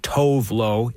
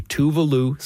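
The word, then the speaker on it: Tove Lo: Now you look at the name on your screen or on paper and you think it's pronounced Tovlo. It's actually Tuvalu, singer-songwriter from Sweden.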